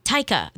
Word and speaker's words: Taika: Taika? – Taika. Yeah, there we go. Taika Waititi.